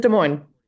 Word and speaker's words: Des Moines: is Des Moines. – as a as an Iowan, the Des Moines pronunciation takes some learning.